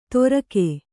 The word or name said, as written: ತೋರ್ಕೆ